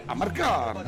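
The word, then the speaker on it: Amarcord: Però in realtà la pronuncia esatta sarebbe Marcord, mi ricordo, no, di quella cosa.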